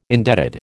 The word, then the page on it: indebted: American English